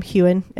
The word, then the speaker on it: Heughan: played by Sam Heughan, and it's spelled H-E-U-G-H-A-N.